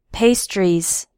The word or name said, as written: pastries